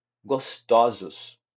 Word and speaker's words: gostosos: gostoso, gostosos. Nesse caso de gostoso Percebe-se que na segunda sílaba é que muda a pronúncia de aberta para fechada. Gostoso, gostosos.